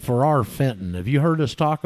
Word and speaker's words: Ferrar Fenton: Well, Ferrar Fenton was an Englishman, F E R R A R F E N T O N, exactly like it's spelled,